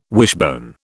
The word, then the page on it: wishbone: British English